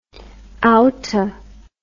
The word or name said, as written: outta